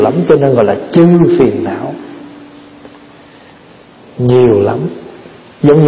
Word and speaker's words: chư: Cái hôm trước mình học rồi đó, thay vì chữ trừ đó, đúng ra phải đọc là chư phiền não mới đúng, chứ không có trừ.